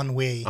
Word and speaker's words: ennui: Oh, ennui.